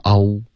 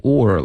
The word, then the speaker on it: all: Are embarrassed, 这个嗯的音没听见，然后 all, 结尾舌尖要顶着上额，不能发 all. – Megan. – All kids are embarrassed by their parents. – 啊，各种发音都有，现在变成 or 了。 OK, 它不是一个 or, or 是 or 的音，这个是 all, 结尾呢，舌尖要顶着上额 all.